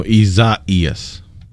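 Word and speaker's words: Isaías: It would appear from the way it's typically pronounced, spelled I-S-A-I-A-S. So, seems like we kind of insert an extra E in there. Between the I and the A?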